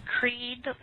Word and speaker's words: Creed: Creed Loeffler.